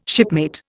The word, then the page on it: shipmate: Indian